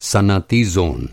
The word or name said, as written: सनअती